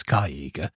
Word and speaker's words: Cyäegha: So, I mean, it's starting with a C, so because it's CY, I'd assume that's a soft C, but I've heard Matt pronounce it as Kaiga. Mm-hmm. So mm. you could potentially look at it that way, but any word I can think of in English off the top of my head that begins with CY is generally a soft C.